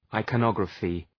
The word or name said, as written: iconography